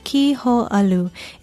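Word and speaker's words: ki ho alu (kī hōʻalu): Aloha, I'm Leilani Poliahu of Hawaii Public Radio, and our Hawaiian word for today is ki ho alu. It is spelled K-I, the Hawaiian word for ki, and H-O, okina, A-L-U, meaning to slacken or loosen, and it is the popular Hawaiian term for what in English we call slack ki.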